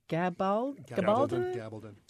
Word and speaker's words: Gabaldon: Gabaldon.